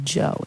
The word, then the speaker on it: Djo: And Gap Tooth Smile by Joe is how you pronounce it. It's spelled D-J-O, and a lot of people pronounce it like that, but that's technically wrong, I think.